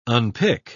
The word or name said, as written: unpick